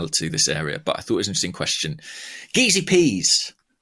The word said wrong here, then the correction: Jeezy Peas: Hang on. It's apparently Jeezy Peas. – Oh, of course. Yes, it was pointed out. It's corrected. Jeezy Peas. – It rocked my world. – To rhyme with cheesy peas.